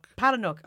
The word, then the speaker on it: Palahniuk: Fight Club is a 1996 novel, and I'm going to butcher this guy's name, by Chuck Palahniuk? – Palahniuk.